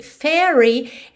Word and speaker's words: fairy: Aqui, fairy, essa palavra F-A-I-R-Y, esse A-I você pode pensar como um E, com acento agudo. Fé, fairy, ok?